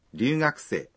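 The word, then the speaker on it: Ryugakusei (留学生): Ryugakusei. – Là du học sinh. – Ryu trong ryugakusei phát âm hơi giống như ryu, mặc dù khi viết phiên âm thì là r.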